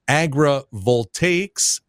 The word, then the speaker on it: Agrivoltaics: It's called, and correct me if I get this wrong, the Midwest Agrivoltaics for Resilient Communities Initiative.